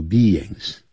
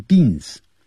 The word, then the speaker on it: beings: 错的。一开头讲We are social beings, 我们是社会人。这个 beings, 记住就是生物的意思，社会的生物。这个 beings, 我们常常用它呢代表生物，尤其呢加了 s, 指的是人类，就是 human beings。所以你这个也可以改成 we are social human beings。human 就是人，人的 hu,H-U-M-A-N Human beings Beings 先念 B 再来一个 ings Beings 不要念 beans 因为 B-E 念成 B I-N-G 念成 N